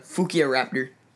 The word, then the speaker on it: Fukuiraptor: One of my favorite names is the Fukia Raptor. – The weird name. – It's spelled Fukuiraptor, but it's not even part of the raptor family.